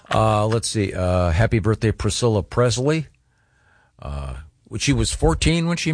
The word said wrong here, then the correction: Presley: Why are you mispronouncing Presley? – What did I say? – Presley. – It's a hard Z.